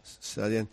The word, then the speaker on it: Sallent: 25 años, naces en Llobregat, en Sallent o Salén, ¿cómo se pronuncia? – Sallent.